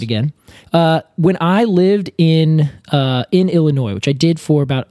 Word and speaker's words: Illinois: O-I-N-E-N-S-I-S. So it's meaning the caria, which is the nut, from Illinois. – Okay. – Now, Illinois is pronounced Illinois, even though it has like the I-S at the end, right? – Right. – O-I-S. – Is that the French? – It is, yeah.